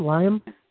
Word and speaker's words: Liam: L-I-A-M? Liam? – Liam.